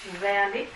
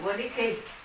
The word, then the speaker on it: Wernicke: Đây là chữ tiếng Đức. Người Đức thì đọc là Wernick, mà Mỹ đọc theo Mỹ thì Wernicke.